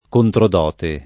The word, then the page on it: controdote: lingua italiana